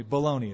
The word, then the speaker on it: baloney: That's balagna. Oh, excuse me, baloney it's pronounced.